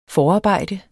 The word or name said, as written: forarbejde